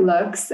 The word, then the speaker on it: Loox: Yes, I work at Lux.